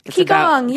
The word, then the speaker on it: Qigong: Qigong, yes. – Qigong, yes, moving energy fields.